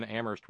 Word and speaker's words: Amherst: Terry in Amherst, where they don't pronounce the H.